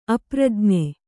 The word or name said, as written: ಅಪ್ರಜ್ಞೆ